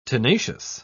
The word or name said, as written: tenacious